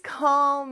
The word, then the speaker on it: call: 자, 요거 발음할 때 조금 어려운데요. C-A-L-L 이잖아요. 요 L 발음을 할 때 조금 어려워요. 혀가 윗니 뒤쪽에 쭉 와가지고 붙지만 혀의 뒤쪽은 약간 목구멍을 닫는 듯한 느낌으로 O 요런 발음이 됩니다. 같이 한번 해볼까요? O O 자, 앞에 call을 넣어서 call call 요 느낌이에요.